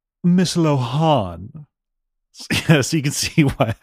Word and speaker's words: Lohan: I don't think I had dreamed it though, that is how you're supposed to say it. That's how her name is pronounced. Lohan.